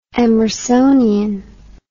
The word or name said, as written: emersonianism